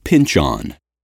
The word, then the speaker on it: Pynchon: First of all, it's pronounced pinch-on.